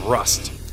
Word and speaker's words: Wrust: Wrust, and that's Wrust with a W, a silent W.